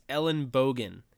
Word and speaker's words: Ellenbogen: Yeah, so it is Ellenbogen. It actually means elbow in German. But people will call me Ellenbogen. I've gotten Ellentoboggen, which there's no T in case you didn't know that.